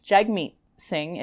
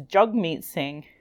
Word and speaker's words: Jagmeet Singh: So anyway, so one thing I want to say is that up to this podcast, I know I have been saying the leader of the NDP's name wrong. So it's not Jagmeet Singh, it's Jagmeet Singh.